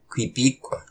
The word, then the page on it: Qipiq: Y’all turn!